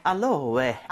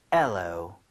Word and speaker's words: aloe: 알로에. – 알로에. – 아니라 발음은 엘로입니다. – 뭐요? – N은 아예 발음이 없고요. 엘로.